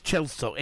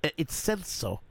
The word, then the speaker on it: Celso: And I've said that right, but everyone says Chelsea. It's Celso.